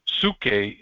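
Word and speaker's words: psuche (ψυχή): The Greeks called psuche. We pronounce it psyche in English today.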